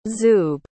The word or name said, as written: zoob